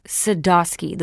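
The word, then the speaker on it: Sadoski: Sadowski, the way it's spelled. There's no W.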